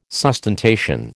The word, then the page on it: sustentation: American English